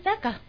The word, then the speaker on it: Sucker: ¿Está bien dicho? Yo todavía sigo teniendo problemas con mi pronunciación en inglés. A ver, dime, amiga, ¿cómo se dice la canción? – No, pues sí, saca. ¿Sí? – Saca. Ajá, entonces vamos bien a escucharla y volvemos con más del Son del Pop.